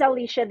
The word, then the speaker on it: Alicia: It is Alicia, the Indian version of Alicia, not the very common Spanish version.